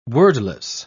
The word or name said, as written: wordless